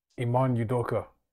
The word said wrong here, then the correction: Ime Udoka: Ime Udoka.